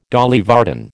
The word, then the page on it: Dolly Varden: American English